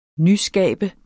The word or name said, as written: nyskabe